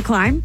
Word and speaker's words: climb: It's the climb? – Yeah, it's the climb. – Climb. – C-L-I-M-B. It's a funny word, now that we're looking at it. – Yes. – C-L-I-M-B. The B is silent.